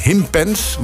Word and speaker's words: Himpens: Het is heel ander weer hier in Himpens. Want zo moet je het eigenlijk uitspreken daar zuidelijk van Leeuwarden.